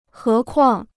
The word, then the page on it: 何况: hé kuàng